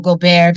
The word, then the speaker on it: Gobert: I feel like in the South and way up North, they say Gobert, and it goes with my whole sexy genre, Nicole Gobert, very French.